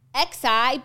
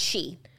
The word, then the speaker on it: Xi: It's XI, but I think it's pronounced Xi.